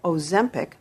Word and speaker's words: Ozempic: Ozempic，它的中文名啊叫诺和泰，是制药公司诺和诺德制药公司推出的新型可注射的处方药。